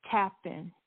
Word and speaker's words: Tappin: Tappan. T-A-P-P-I-N. – T-A-P-P-I-N.